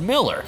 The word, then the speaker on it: Mueller: And then this thing here, Louis Mueller's is pronounced Miller. That is true. It's a German name that should be pronounced Miller.